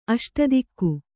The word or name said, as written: ಅಷ್ಟದಿಕ್ಕು